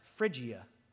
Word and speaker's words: Phrygia: Hey, this next word is pronounced Phrygia. Y'all know how I know that? I Googled it. Phrygia and Pamphylia,